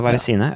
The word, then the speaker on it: Varesine: Vi kan nevne tre Vali eh, Verezin. Er det ikke det sånn det uttales? Varesine? – Tre eh? Valli Varesine. – Ja, Varesine, ja, ikke mm.